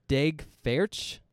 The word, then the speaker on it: Daeg Faerch: Daeg Faerch. D-A-E-G. – Okay. – F-A-E-R-C-H.